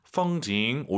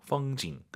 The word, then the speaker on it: 风景: 펑징 올라간 순간 엄밀히 말씀드리면 틀린 발음이에요. 뒤에가 사성이잖아요. 반삼성이죠. 펑징 끝나야 됩니다.